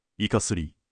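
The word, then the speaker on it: 坐摩: 社の名前を、イカスリ、と読みます。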